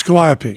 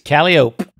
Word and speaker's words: Calliope: How'd you pronounce Calliope? – It is Calliope. – It is Calliope. – Yes. Yeah, yeah, yeah. – In the carnival, it's a Calliope. – They say Calliope.